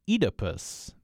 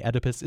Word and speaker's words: Oedipus: Then is Oedipus, although the survey says it's pronounced Oedipus. The OED tells me Oedipus is fine as an American pronunciation, so I'm sticking with that.